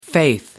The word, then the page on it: faith: American English